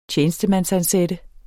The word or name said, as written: TJENESTEMANDSANSATTE